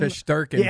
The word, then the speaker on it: Shesterkin: Shesterkin.